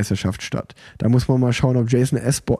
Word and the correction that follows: Osborne: äh Osborne, Jason Osborne, einmal richtig ausgesprochen, seinen WM-Titel verteidigen kann.